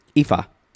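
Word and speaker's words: Ifá: She grew up Catholic, but now she is, it's pronounced Ifa? – Ifa. Ifa. Yeah, a Yoruba religion from Africa.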